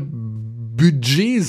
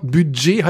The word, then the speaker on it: Budget: Der hat gesagt, ich soll doch endlich mal Budget sagen, weil da gibt's das Wort Budget hat ein D in der Mitte und das D soll man aussprechen. Ich würde immer Budget sagen.